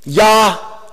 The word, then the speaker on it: Yahweh: Hebrew does not have a W, although it's often pronounced with a W. Yahweh. Yahweh.